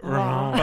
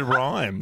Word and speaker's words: Reims: No, Reims. I think you said rhymes.